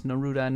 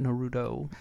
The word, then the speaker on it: Neruda: Before we get to this, I apologize for mispronouncing his last name. I know it's Neruda, not Naruto. – Pablo Neruda.